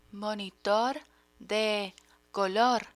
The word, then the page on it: monitor de color: Spanish